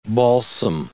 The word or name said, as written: balsam